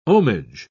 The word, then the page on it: ohmage: British English